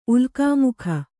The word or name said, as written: ಉಲ್ಕಾಮುಖ